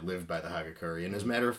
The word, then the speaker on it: Hagakure: The Hagakure, I'm not sure if I'm pronouncing that right. Hagakure? – Sounds right. – That's about right.